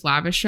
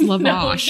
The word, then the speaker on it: lavash: I say lavish. It's actually lavish. I learned that. Did you know? Yeah. Someone corrected me on stories and they were like, you know, it's pronounced lavash.